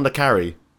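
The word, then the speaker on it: le Carré: The second person to correct me on the pronunciation. It's John Le Carre. I'm such an idiot. – I think so, yeah. – Anyway, John Le Carre.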